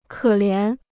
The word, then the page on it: 可怜: kě lián